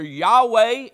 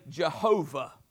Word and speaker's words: Yahweh: One of the interesting parts of this study is they took some of those Hebrew vowels in the word Adonai and inserted them in the YHWH so that we could actually sound out the name of God. And it's come to be known as either Yahweh, as how the Jewish people would pronounce it, and we would say Jehovah.